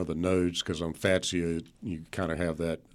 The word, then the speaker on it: Fatsia: And Zach writes, what's your recommendation for pruning cold-burned new growth on Fatsia? Am I pronouncing that correctly? – Yes.